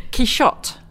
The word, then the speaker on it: Quichotte: Can you pronounce it? – Quichotte. – Quichotte.